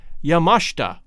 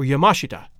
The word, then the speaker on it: Yamashita: But the majority of documentaries, as well as a search of how to pronounce that word online, suggest that the correct pronunciation is Yamashita or Yamashita, with the accent on the second syllable, mash.